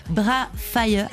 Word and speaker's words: Bra Fie: On y retrouve un duo avec Damian Marley, Bra Fire. Alors je sais pas si je prononce bien, B-R-A-F-I-E.